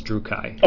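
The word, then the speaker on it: Druchii: the Druki or Druchi or however you pronounce it. – I've on the computer game Battle March, I've heard it pronounced as Drukhai.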